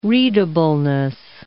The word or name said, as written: readableness